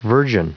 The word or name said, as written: virgin